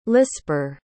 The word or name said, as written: lisper